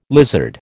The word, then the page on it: lizard: American English